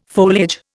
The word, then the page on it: foliage: Indian